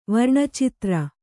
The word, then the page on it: ವರ್ಣಚಿತ್ರ: kannada